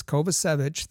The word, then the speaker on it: Kovacevic: But he said it's Kovacevic. That's how his name. He wishes it to be pronounced.